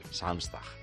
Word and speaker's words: Samstag: Samstag.